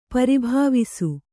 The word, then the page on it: ಪರಿಭವಿಸು: pari bhavisu